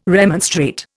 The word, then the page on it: remonstrate: Indian